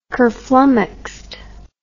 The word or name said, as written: kerflummoxed